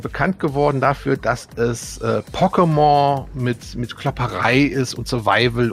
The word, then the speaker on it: Pokémon: Also es wird Pokémon ausgesprochen. Ich kenne so ein bisschen, weil ich habe ein paar Sachen davon gespielt.